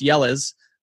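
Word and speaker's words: Jelle's: And so he started playing it on the show, and it's how you spell it is Jelles, but it's pronounced Yellas Marble League.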